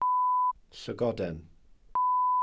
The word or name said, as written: llygoden